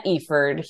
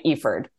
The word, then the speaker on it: Efird: We have Elena Eford here. Said her name right. Eford.